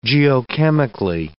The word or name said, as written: geochemically